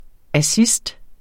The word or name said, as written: assist